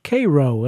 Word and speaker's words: Cairo: Last week we had a segment of the show on Illinois, which included a song about Cairo, Illinois. It's pronounced Cairo, even though it's spelled the same as Cairo, the city in Egypt, which is where we're headed now in this segment, courtesy of Art Hickman's orchestra.